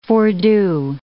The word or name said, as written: fordo